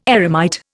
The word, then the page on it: eremite: Indian